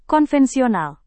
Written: konvensional

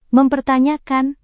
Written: mempertanyakan

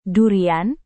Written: durian

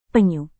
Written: penyu

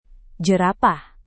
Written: jerapah

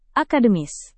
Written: akademis

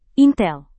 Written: intel

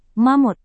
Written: mamut

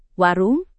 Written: warung

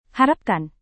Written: harapkan

